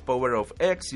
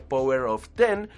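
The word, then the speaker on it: Powers of X: Mientras que en Power of X, este que por la pronunciación en el video resulta que no es Power of X, sino Power of Ten por el número romano.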